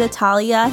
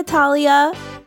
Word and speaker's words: Attaliah: I'm here with Attaliah. Hey Attaliah.